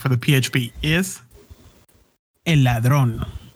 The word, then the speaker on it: Ladrón: Lathrone? – Ladrón. – Is that how you spell it? – Nope. – Well, how do you spell it? quit making me look like a freaking gringo. Oh, whatever. – L-A-D-R-O-N. Ladrón.